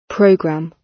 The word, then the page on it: program: British English